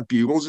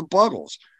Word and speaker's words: Buggles: There's two G's in there. It's not the Bugles, it's the Buggles.